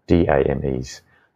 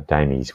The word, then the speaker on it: DAME: Uh, in Australia, they're actually called DAMEs, uh, Damies. We won't pronounce it Dame because it just does, that feels a bit weird.